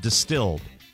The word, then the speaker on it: Dstld: It's called Distilled. It is spelled D-S-T-L-D.